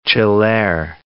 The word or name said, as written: ciller